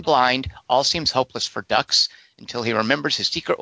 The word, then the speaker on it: Dux: But his name is Dukes. – Anime for white people. – No, it's Dux. What are you talking about? D-U-X, Dux. Rhymes with Lux, the detergent.